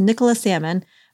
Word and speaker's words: Nicola Salmon: Let's hear this episode's letter and also hear from Nicola Salmon, who I mispronounced her name in the next segment before I actually learned how to pronounce it. So it's Nicola Salmon, but she has some amazing wisdom for anyone who is experiencing infertility and wants to use a non-diet approach.